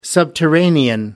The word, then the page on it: subterranean: American English